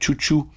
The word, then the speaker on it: Xiu Xiu: On commençait avec Chouchou, groupe qui s'écrit X-I-U-X-I-U.